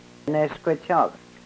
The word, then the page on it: neskwechok: Yurok